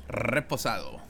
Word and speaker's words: reposado: Reposado. – Reposado.